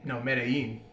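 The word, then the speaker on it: Medellín: Did I say it right? Medellin. – Yeah, that's good.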